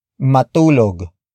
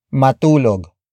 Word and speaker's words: matulog: Ayaw is spelled as A-Y-A-W. It means to refuse. The next word is matulog. Matulog. Matulog. Matulog is spelled as M-A-T-U-L-O-G.